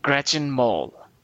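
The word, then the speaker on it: Gretchen Mol: Gretchen Mole.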